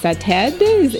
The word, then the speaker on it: SATED: I guess we would call it SATED. Am I pronouncing that correctly? – Yep, that's fine, yes.